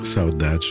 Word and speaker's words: saudade: Pierre Barou sur la Saudade, comme ça qu'on prononce. – Saudade, oui.